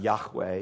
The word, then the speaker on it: Yahweh: Yahweh is the Hebrew pronunciation of it.